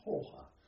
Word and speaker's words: Hooge: I pronounce it Hooge, although my wife will tell you it's pronounced Hoha. It's taken from a battle in Belgium. H-O-O-G-E.